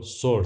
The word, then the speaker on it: ثور: ما ثور رو سور تلفظ میکنیم، اونم به خاطر اینه که توی زبان فارسی برخلاف عربی صدای سه محلی از اعراب نداره و همون سه تلفظ میشه.